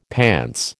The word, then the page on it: pants: American English